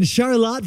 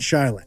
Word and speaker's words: Charlotte: And Rich Sturm down the road in Charlotte, Vermont. Happy birthday. – Great pronunciation. – I stuck the landing. – Don't call it Charlotte.